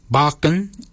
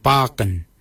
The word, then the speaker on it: baginy: For beard, we say baken. Baken is spelled B-A-G-I-N-Y. Baken. Baken is your beard. The upper mouth, we say warago. Warago. Warago is spelled W-O-R-R-A-G-O-L.